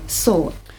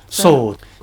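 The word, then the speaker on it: sword (סוד): 上帝的密旨，密旨这个字呢，它的希伯来语是 sword. – It's very easy to pronounce, sword. – 很容易读的啫， sword. – But it doesn't mean a weapon, a sword.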